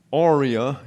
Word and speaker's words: Aurea: Aurea is the way that is pronounced.